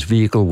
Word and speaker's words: vehicle: Their preferred pronunciation is vehicle without the H. – Yep. – They say with the H as an alternative, but they list them in that order. So the preferred pronunciation is without the H, and calling it vehicle, they say you can do that if you want to, but clearly it's not their first choice.